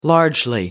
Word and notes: largely: American English

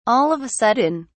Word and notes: all of a sudden: British English

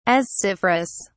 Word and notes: asciferous: British English

